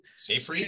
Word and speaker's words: Seyfried: So it's got Amanda Seyfried. Is that how you pronounce it? – Seyfried.